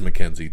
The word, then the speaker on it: McKenzie: I'm trying to remember how to even pronounce her name. McKenzie. It is McKenzie.